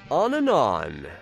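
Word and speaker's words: On An On: Today, we're introducing you to a band called On and On. No, I'm not mispronouncing the word and. It's actually on, an, A-N, on.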